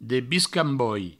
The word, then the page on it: debiscamboi: Provençal